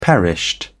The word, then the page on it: perished: British English